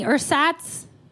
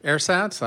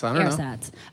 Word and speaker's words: Ersatz: No, I pronounced that incorrectly. – That's a pronunciation. – It's a pronunciation. That was real. Oh, that was nice. That was very. That feels much worse. Okay, how do you really pronounce that? – Ersatz.